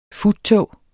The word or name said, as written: FUTTOGES